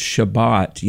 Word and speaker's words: Shabbat: You know, the Hebrew word sabbat, and in modern times it's pronounced Shabbat,